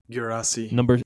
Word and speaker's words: Guirassy: Guirassy. – Yeah, Guirassy.